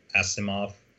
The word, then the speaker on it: Asimov: So I recently started reading Foundation, which is a sci-fi book by Isaac Asimov.